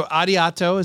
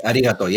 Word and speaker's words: arigato: So arigato is how we pronounce that, right? – Yes, arigato, yes.